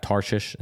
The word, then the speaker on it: Tarshish: And if you look at it and look at the maps and stuff like that, Tarshish, is that how you pronounce it? – Mm-hmm.